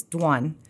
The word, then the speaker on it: Dawn: growing up, it's two syllables. It's Dwan.